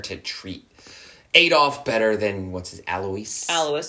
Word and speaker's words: Alois: So he had a half-brother, Alois Hitler Jr. We think we're pronouncing that correctly. – Alois, yeah.